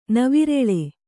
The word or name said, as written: ನವಿರೆಳೆ